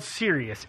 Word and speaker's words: Sirius: called Sirius S I R I U S I believe is how you pronounce it.